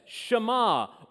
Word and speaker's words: Shema: He says, Shema, or hear, O Israel, the Lord our God, the Lord is one.